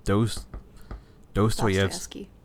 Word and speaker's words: Dostoevsky: Dostoevsky.